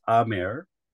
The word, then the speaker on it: Aamar: It actually is Aamar and Khwaja.